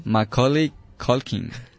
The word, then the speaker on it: Macaulay Culkin: Macaulay Culkin.